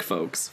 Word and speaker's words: folks: I like folks. Folks is good. – Folks is good. – Folks is good, but some people pronounce the L in folks, which really folks.